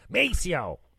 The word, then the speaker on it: Maceo: Wasn't sure of Maceo's name, mispronounced it, and Van corrected me immediately with a little little bit of attitude. Maceo, not Maceo.